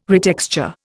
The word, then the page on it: retexture: Indian